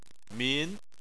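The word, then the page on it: min2: tone 2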